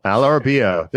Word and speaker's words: Al Arabiya: Al Arabiya. There you go. It's not that hard. – Sorry, geez. – It's only like three syllables.